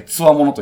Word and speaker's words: 強者: うんうん、これは強者と書いて、つわものと読むんですかね。なるほど。の異名を持っています、えー、昔からヨーロッパでも有数の港町、ニシン漁で知られていました。